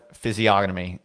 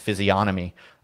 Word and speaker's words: physiognomy: But soy dev physiognomy is what he said. I always thought it was pronounced physiognomy. I thought the G was a soft G.